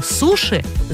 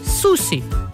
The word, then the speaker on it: суши: И в словарях даже указывают два способа произношения – «суши» или «суси». Так больше похоже на звучание в японском языке.